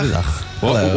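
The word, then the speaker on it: Guten Tag: Gute Dach.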